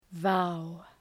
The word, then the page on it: vow: vaʋ